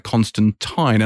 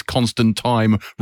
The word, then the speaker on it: Constantine: it was repeated numerous times that it is Constantine rhymes with rhyme. because apparently that's the more common British pronunciation, whereas Constantine is more of an American thing, which is why the Keanu Reeves character is Constantine.